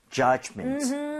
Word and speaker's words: judgment: 그리고 U의 강세를 주고요. D 사운드는 굳이 세게 낼 필요가 없어요. 그래서 그냥 judge, judgment. 이 정도도 충분합니다. – 네. – Okay? 자, 그러면 judgment 발음 한번 해볼게요. D 사운드 크게 내지 마시고요.